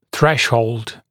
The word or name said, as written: threshold